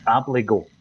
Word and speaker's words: Abildgaard: And by the way, I thought you were going to bring in the other signing from Rubin Kazan because we were informed that his name is Abligo. So there you go. Oh, um, okay. Um, I, have been calling him Abildgaard. Apologies for everybody that uh, knows the correct pronunciation.